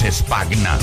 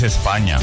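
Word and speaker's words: Spagna: Sí, sí, aquí la llamábamos España, pero no, es España, se pronuncia así.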